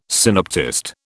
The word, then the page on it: synoptist: British English